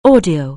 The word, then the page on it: audio: anglais